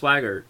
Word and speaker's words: Swigert: I always call him Swigert for some reason. – No, it's pronounced Swigert. It's spelled Swigert.